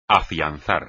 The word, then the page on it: afianzar: espagnol